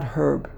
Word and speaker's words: herb: You're not supposed to pronounce the H, right? An herb, you don't say herb, you pronounce herb.